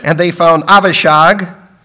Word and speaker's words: Avishag: That's a nicer way to pronounce the name, isn't it, than Abishag? I just couldn't think, can you pronounce this Abishag? And so I'm going in Hebrew, and we know it's Avishag.